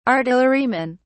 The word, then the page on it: artillerymen: British English